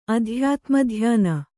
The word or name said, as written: ಅಧ್ಯಾತ್ಮಧ್ಯಾನ